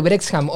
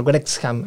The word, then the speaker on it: Wrexham: Eh, todo lo que rodea al Brexham hoy, en, este momento al Brexham, en este momento es, es muy divertido. Se pronuncia raro porque es WR, es como decir rap.